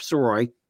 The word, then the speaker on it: Sirois: You can find me under um, under the same um, the same name at George Sirois.